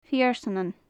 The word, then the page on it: feursnanan: gaelic